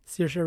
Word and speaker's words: Saoirse: Saoirse Ronan, yeah.